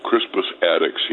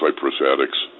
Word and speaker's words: Crispus Attucks: Crispus Attucks, he pronounced Cypress Attucks.